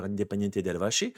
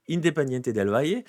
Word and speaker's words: Independiente del Valle: On va le prononcer pas à l'Argentine. Si vous avez un Argentin qui vous parle, il va vous dire Independiente del Valle. Mais bon, Independiente del Valle.